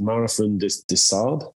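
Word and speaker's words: Marathon des Sables: Is like is it Marathon de Saab? – Yeah, very good.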